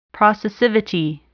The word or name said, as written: processivity